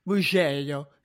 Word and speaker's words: Mugello: I mean, I'm excited for all of the new races for a number of different reasons, and that includes Mugello, as Italians will clearly agree with me, that's how it's pronounced.